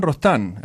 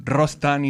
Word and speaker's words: Rostan: In Hernan Rostan. Hernan with the H. So I will spell it. H-E-R-N-A-N. And Rostan is R-O-S-T-A-N.